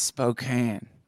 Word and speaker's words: Spokane: It's Spokane.